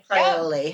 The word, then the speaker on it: Fraioli: It's spelled F-R-A-I-O-L-I. – F-R-A-I-O-L-I. – Crioli.